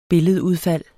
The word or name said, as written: billedudfald